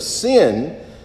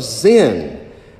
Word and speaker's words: Zin: you will quickly find out that the wilderness of sin is more appropriately pronounced in the Hebrew as a wilderness of Zin.